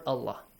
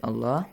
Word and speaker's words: Allah: So the lam in the word Allah is pronounced with an ah sound.